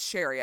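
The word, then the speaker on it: Cherie: The song Cherry Bomb was written with this in mind, but probably never would have happened if Cherry picked... Is it Sherry? It's Sherry. I keep calling her Cherry. It's Sherry. – It's Sherry, but it's spelled like Cherry.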